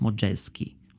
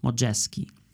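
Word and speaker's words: Modjeski: Dlatego przyjmują nazwisko, pisze się Modjewski, czy, czyta się modże, modżejski. E, I tak jest właśnie tam potem określany w Stanach i zmienia również imię na trochę łatwiejsze, na Ralph. Więc jest Ralph Modżeski.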